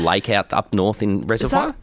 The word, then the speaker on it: Reservoir: It's certainly not got the French influence pronounced in it. Reservoir. – Oh, reservoir. – Because it's spelled like au revoir.